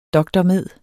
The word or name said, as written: dr.med.